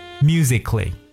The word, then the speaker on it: musically: 那么这三个单词大家仔细听一下：romantically、 musically、 logically。这里边 k 的发音都是由字母 c 来构成的，所以在这里边的字母 a 呢是不发任何音的。